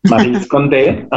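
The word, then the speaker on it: Maryse Condé: This week, I read this memoir by Ma- Marie Condé, which I always uh, named her as Marie Condé, but today I I thought she, she writes in French and it's a French name, so maybe it's a different pronunciation. So Angela helped me with that.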